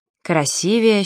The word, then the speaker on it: красивее: Ударение в сравнительной степени «красивее» падает только на второй слог, и никак иначе. «Красивее, счастливее».